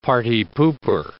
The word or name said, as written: party pooper